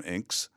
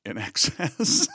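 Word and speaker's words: INXS: I plainly remember calling them Inks. In excess.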